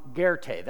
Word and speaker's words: Goethe: Guy by the name of Johann Wolfgang von Goethe, that's how you pronounce that. I just learned that. I didn't know that's how that was pronounced.